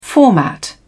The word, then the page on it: format: British English